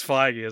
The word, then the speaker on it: Feige: I guess it's Feige, isn't it? I was about to say, how has no one said Feig fatigue yet until now? But it's because it's not pronounced that way.